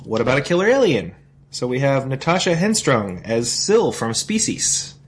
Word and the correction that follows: Henstridge: Henstridge? – How the hell do you pronounce her name?